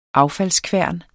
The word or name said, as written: affaldskværn